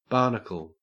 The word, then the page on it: barnacle: Australian English